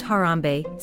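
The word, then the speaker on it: Harambee: Then the cup is passed to each family member, who sips while everyone chants Harambe, Swahili for "let's pull together," and pronounced Harambe 7 times.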